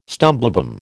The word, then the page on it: stumblebum: American English